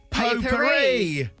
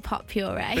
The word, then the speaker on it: potpourri: I call it pot puree. – But yeah, that's exactly. My mum calls it pot puree. Like it's the puree of something. – And because that's how it's spelled, isn't it? – Right. Yeah. And I literally, it was literally a couple of weeks ago, I realised that wasn't a thing.